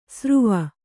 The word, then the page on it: ಸರುವ: saruva